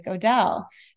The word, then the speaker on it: Odele: And I'm like, oh, you know, and I played it like, this is how you pronounce it. And it sounds like Odele.